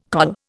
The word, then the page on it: col: Indian